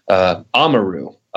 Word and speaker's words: Amaru: But in reality, the name America is made up of a root word, Amaru, depending on where you are, how you say it, it's A-M-A-R-U.